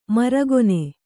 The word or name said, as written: ಮೆರುಗೆಣ್ಣೆ